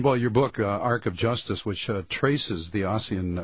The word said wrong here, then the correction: Ossian: I've been mispronouncing his name all the time. It's Ocean.